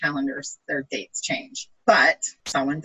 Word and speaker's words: Samhain: So typically pagans start the Wheel of the Year at Samhain, which is spelled S-A-M-H-A-I-N, pronounced the Celtic way, Samhain.